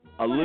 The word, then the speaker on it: Aliviyah: A-L-I-V-I-Y-A-H. – Olivia? What the fuck? – Olivia. – What's the matter with pronounced like Olivia Newton-John, but it's A-L-I-V-I-Y-A-H. Shut up. Okay. Number four. Marich. M-H-A-V-R-Y-C-H.